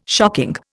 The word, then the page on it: shocking: Indian